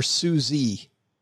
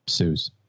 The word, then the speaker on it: Suze: Do you pronounce it Suze or Susie Orman? – Suze. – Suze Orman.